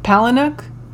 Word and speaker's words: Palahniuk: Can you spell it? P A L A H N I U K. Palinuk? Sure.